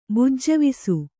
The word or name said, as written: ಬುಜ್ಜವಿಸು